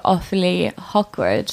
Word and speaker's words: Ophélie Hocquard: But yeah, in English, it actually translated into Ophélie Hocquard.